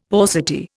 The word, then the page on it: paucity: Indian